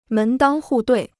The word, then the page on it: 门当户对: mén dāng hù duì